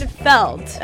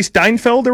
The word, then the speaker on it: Seinfeld: Seinfeld.